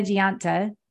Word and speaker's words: Gionta: Dr. Dana Gianta, did I say that right? – Yes, that's right.